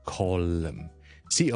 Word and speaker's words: column: 一栋栋嘅 column 啦，或者系报纸里面一个专栏，我叫 column，c o l u m n column。咁呢个咧都系一个 silent m 嚟嘅，当系 l u m column。